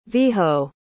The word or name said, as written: Viho